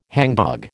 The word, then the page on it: hangdog: American English